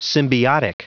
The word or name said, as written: symbiotic